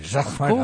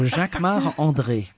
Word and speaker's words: Jacquemart-André: Jacques or no, you say it like André. A Frenchman. – Jacques-Marc-André. Of course, because I'm French. So Jacques-Marc-André.